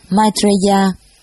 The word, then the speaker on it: Maitreya: tiếng Ấn Độ đọc là Maitreya.